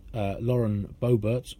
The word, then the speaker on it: Boebert: On student loan forgiveness, it prompted a rather extraordinary comment from, any guesses, a Republican politician, Lauren Boebert from Colorado. Have I pronounced that right? – Yes, you have.